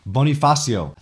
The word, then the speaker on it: Bonifacio: Andres Bonificio. Bonifacio. I think I'm mispronouncing that the whole time.